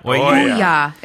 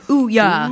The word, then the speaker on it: Ouya: Amazon has a lot of interesting support from game developers in a way that the Ouya, however you pronounce it, Ouya? – Ouya. It's like Ouya.